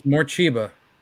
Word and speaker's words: Morcheeba: For slow, sensual tempos, go to 90s trip hop, Massive Attack, Hooverphonic, Morcheeba. Did I say that right? – I know. Morcheeba, yeah, I know who that is.